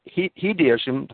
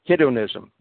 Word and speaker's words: hedonism: Hedeism, the popular philosophy of America, but I don't know what that, did I pronounce that right? – Hedonism,